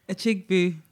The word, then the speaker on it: Achigbu: Achigbu.